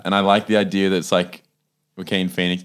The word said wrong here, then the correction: Joaquin: Is it pronounced Yaquin? – Oh, yes. It's a bit late for that, isn't it? – I've been saying Joaquin this whole time.